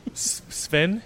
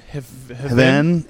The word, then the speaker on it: Hven: Well, how do you pronounce this? – I'm not from Sweden, so I'm going to say Sven. – Hven. – Hven.